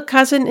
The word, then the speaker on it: Cousin: The male cousin is der Vetter oder der Cousin. We try to pronounce it in a French way.